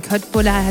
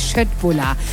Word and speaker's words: Köttbullar: Köttbullar heißt ja eigentlich Schöttbullar. Ja.